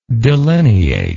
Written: delineable

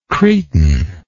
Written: cretin

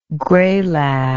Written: graylag

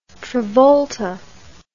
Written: travolta

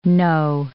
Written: know

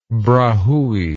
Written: brahui

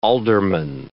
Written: alderman

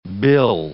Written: bill

